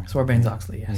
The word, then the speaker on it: Sarbanes-Oxley: They're claiming that this comes from a law called, I'm not going to say this right, but Sarbanes-Oxley Act. – Oh, yeah. Sarbanes-Oxley. – Sarbanes-Oxley, yes.